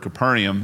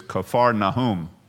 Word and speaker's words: Kfar Nahum: Capernaum, it's not the way you pronounce it. Actually, the way the town is pronounced in Hebrew is Kfar Nahum.